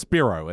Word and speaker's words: Spiro: It's pronounced Spiro. It's a Greek name.